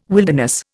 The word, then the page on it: wilderness: Indian